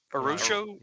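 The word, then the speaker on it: Araújo: Arajo. – Oh gosh, I had it last time. Arusho? – Yeah.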